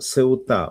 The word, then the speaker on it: Сеута: Оказывается, ударение, как мне сегодня в дневном эфире сказали, что Сеута, ударение на А.